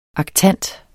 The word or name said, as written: aktant